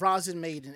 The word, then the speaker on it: Rozen Maiden: And uh, I guess if you've never heard of Rozen Maiden, I don't know why I'm saying it like. – No, you're saying it right. Rozen Maiden.